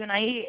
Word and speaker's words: Unaí: Eu estou entrando em contato com o senhor, senhora Marilda, porque a gente está fazendo um convênio de Unaí. É Unaí que fala, né? Que pronuncia mesmo, né? – Isso. – Então, o município de Unaí, ele é conveniado, que é o Santander, e a gente está entrando em contato para passar algumas ofertas, né?